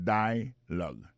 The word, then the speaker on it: dialogue: Alors, mot dialogue, ça c'est dialogue. Ça nous a remarqué là, nous a remarqué que l'accent tonique là tombé sous premier syllabe là.